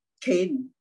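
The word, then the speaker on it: Cain (חן): Although I don't, this is pronounced as Cain, all right? But I saw the word C-H-E-N, okay?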